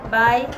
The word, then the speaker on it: bye: Vamos más abajo, bye, o se escribe bui, pero se dice como cuando digo chau, bye.